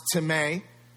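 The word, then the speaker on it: timē (τιμή): The Greek word is pronounced timē, and it looks like time, and it means to value, to respect, or to high esteem, to bring, to treat as precious, weighty, and valuable.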